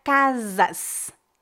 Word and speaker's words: casas: Existem muitas variações diferentes no Brasil, dependendo do sotaque, mas na maioria dos lugares, a letra S em final de palavras ou final de sílabas é pronunciada como um S tradicional, né? Por exemplo, a palavra casas, casas. Como você pronuncia essa palavra em Portugal? – Casas, casas. Então, o S final também se transforma no som X. Ou seja, como se fosse quase um SH, um CH em português também.